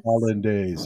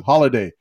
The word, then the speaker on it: Hollandaise: Hollandaise. So I've been saying it wrong all these times. Holiday. I want some of that holiday sauce.